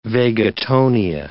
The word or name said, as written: vagotonia